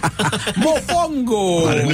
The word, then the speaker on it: Mofongo: Justin, is he saying that right over there? – You're saying it right. – Okay, I just double check. – Mofongo.